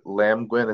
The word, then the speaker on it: Lamgwin: Was it Lamgwin? Is that how you pronounce it? – Yeah.